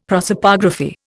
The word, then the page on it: prosopography: Indian